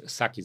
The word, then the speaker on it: Psaki: Saki sagt man, glaube ich. Ne? Saki spricht man sie aus.